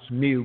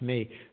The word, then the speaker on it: mu: Yes, that's the Greek mi, which Americans pronounce mu, but it's really pronounced me. So in Greek, The MU is pronounced me and the NU is pronounced knee. It's not pronounced mu and new, but yes.